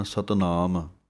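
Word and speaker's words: सत नाम (ਸਤਿ ਨਾਮੁ): सो साध संगत जी इस तरह आप जी ने ध्यान नाल इस पाठ नू उच्चारण करना है। सत नाम, इस पाठ नू कई इकट्ठा पढ़ते हैं सतनाम, लेकिन थोड़ा जिहा स्पेस दे के बोलना है, सत कस के बोलना है सत नाम।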